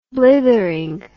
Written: blithering

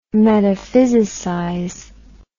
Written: metaphysicize

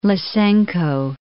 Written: Lysenko

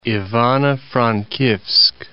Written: Ivano-Frankivs'k

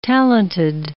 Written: talented

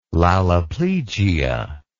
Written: laloplegia

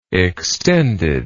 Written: extendedness

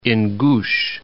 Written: Ingush Republic

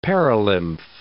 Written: perilymph

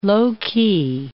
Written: low-key

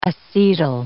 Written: acetyl

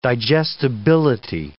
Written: digestibility

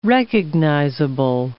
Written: recognizable

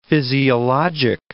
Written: physiologic

